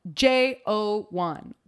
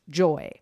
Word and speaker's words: JO1: Okay, first performance of the night, we get J-O-1, which is, I have learned this weekend, not pronounced Joy.